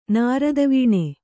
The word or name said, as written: ನಿರ್ಧಾವನ